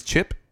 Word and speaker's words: Ch'p: Yes, his name is Chip. It is spelled CH'P.